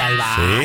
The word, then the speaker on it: albahaca: Poneme un poco de albahaca, ¿cómo se pronuncia? – ¿Y cómo se pronuncia? – Albahaca. – No, nadie dice albahaca. Sí. – Porque se respeta el tiempo de la H animal. – La H intervocálica.